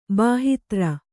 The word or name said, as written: ಬಾಹಿತ್ರ